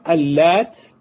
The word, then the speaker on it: اللات: أما اللات فتنطق بتشديد اللام اللات، وقرأت أيضا بتخفيفها اللات.